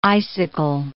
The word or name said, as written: icicle